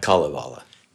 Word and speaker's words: Kalevala: is it pronounced Kalevala? – Kalevala.